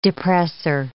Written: depressor

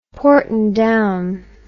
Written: Porton Down

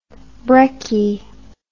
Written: brekkie

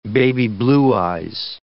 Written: baby blue eyes

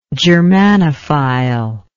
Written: germanophilia

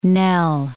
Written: knell

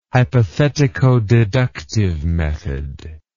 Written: hypothetico-deductive method

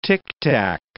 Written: ticktack

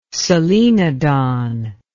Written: solenodon

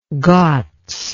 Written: ghats

